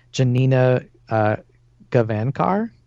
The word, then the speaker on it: Janina Gavankar: I'm going to butcher her name, but it's like Janina Gavankar.